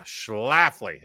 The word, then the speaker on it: Schlafly: It's called Schlafly Pumpkin oh, Ale. Let me just put in four consonants in a row before. – There, here. See? Okay. – Get, oh yeah, Schlafly.